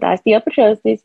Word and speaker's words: osteopetrosis: And at two, I was diagnosed with osteopetrosis. And you said it right, by the way. I know it's kind of hard, but no, that was right.